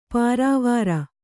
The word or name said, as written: ಪೊರವಾರ್